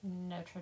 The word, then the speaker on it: Notre Dame: Do you say Notre Dame or Notre Dame? – Notre Dame. – Say it like an American. – I say Notre Dame. – I've always said Notre. Not Notre Dame.